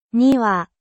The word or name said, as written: niwa